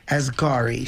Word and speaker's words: Asghari: Asghari.